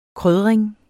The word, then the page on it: krydring: ˈkʁyðʁεŋ; ˈkʁœðʁεŋ